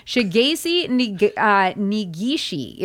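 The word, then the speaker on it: Shigeichi Negishi: Now, I had to go online and figure out the pronunciation because it's Japanese. My Japanese is not what it used to be. Shigesi Nigishi is his name, and he lived to be 100 years old.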